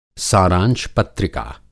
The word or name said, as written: सारांश-पत्रिका